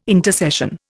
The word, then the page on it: intercession: Indian